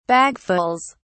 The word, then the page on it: bagfuls: British English